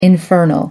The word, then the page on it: infernal: American English